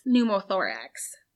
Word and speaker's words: pneumothorax: Well, the collapsing of a lung is pneumothorax. But what? Correct. – Does that start with a silent P? – Yep.